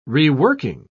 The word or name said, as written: reworking